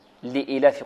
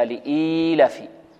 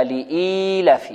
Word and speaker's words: لإيلاف: يقول لإيلاف قريش، وهذا خطأ، بل لابد من مد الكسرة التي على الهمزة وإشباعها حتى يتولد منها ياء، هكذا لإيلاف، أو أن ننطق بياء مدية بعد الهمزة، هكذا لإيلاف، لابد هذا يضغطها القارئ قليلا أو يعمل لسانه فيها كي يتمكن من نطق الياء بعد الهمزة، الياء المدية، هكذا لإيلاف قريش.